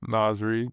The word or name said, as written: naz REID